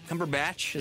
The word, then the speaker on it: Cumberbatch: Cumberbatch, is that how you say it? – Yeah.